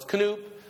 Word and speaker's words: Knoop: The first blank is Knop or Knoop. I've not heard his name pronounced by anybody who really knows how to pronounce it. So it's Knoop.